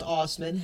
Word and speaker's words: Assman: Now, unfortunately, it is pronounced Osman, however, it is A-S-S-M-A-N.